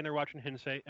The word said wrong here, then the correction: hentai: Or is it Hensei? How do you say that word? – Hentai. – Hentai. Like the Japanese hentai. – Anime. – Oh, hentai.